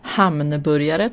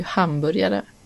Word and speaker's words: hamburgare: Hamnburgare trodde jag att det hette. Det heter ju hamburgare. Jag trodde det var någonting med hamnen.